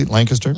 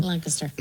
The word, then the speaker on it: Lancaster: By the way, you love how I'm saying it correctly, Lancaster? – Lancaster.